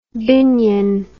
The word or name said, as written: binyon